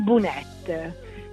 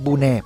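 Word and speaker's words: bonet: ditela quella T, non dite bunè, perché bunè non ve lo danno.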